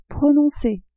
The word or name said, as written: prononcer